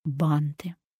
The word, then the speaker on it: банты: Итак, грамотно ставим ударение на первый слог в словах «банты» и «туфля».